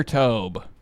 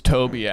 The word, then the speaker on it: Tobe: Would you say Toby or Tobe? I say Tobe. – Tobe. – But it's Toby, actually.